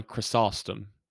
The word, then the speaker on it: Chrysostom: I've always heard it's John Chrysostom.